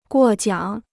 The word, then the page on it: 过奖: guò jiǎng